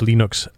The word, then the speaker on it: Linux: I pronounce Linux.